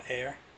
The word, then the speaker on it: Ayer: Ayer? – Yeah, Ayer.